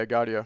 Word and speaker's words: Gaudio: Yeah, Gaudio.